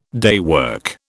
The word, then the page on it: daywork: British English